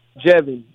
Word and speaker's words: Jevin: S-T-R-E-E-T-O-R-P-H-A-N, Street Orphan. – And your name? – Jevin. J-E-V-I-N-L-A-M-A-R.